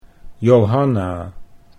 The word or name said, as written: Johanna